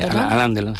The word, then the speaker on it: Alain Delon: Sí, Alain Delon.